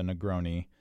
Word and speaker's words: Negroni: The cocktail is, it's spelled N-E-G-R-O-N-I, first word. Second word, S-B-A-G-L-I-A-T-O.